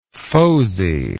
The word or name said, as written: foziness